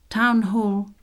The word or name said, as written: town hall